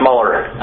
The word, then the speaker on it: Mueller: Director Mueller, the FCIC was a... – Can I just say, it's actually pronounced Mueller. – Oh, Mueller.